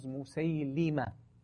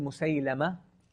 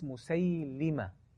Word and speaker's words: Musaylima: Okay, the proper pronunciation is Musaylima. It's commonly said Musaylama. It's been like mentioned, this kind of pronunciation, but it's Musaylima.